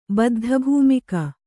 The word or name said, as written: ಬದ್ಧಭೂಮಿಕ